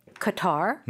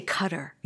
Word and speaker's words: Qatar: And by the way, Americans say Qatar, mm-hmm. or they also say cutter. – Mm-hmm. – There are two pronunciations.